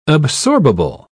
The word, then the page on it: absorbable: British English